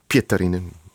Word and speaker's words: Pietarinen: Of Pietarinen, moet je dat in Fins altijd de klemtoon op de eerste letter doen.